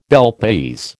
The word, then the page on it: Bel Paese: American English